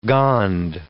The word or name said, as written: Gond